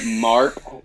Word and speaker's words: Mark: Mark Vitti.